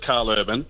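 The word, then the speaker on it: Karl Urban: It is just Carl Urban, for the record, but over in Europe, which is where half of my family originated from, it's pronounced the other way. So, I just say it the way it looks.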